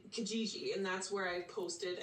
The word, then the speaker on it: Kijiji: Kijiji.